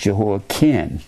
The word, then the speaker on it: Jehoiakim: There's an I-N and a C-H. But this is pronounced Jehoiakim.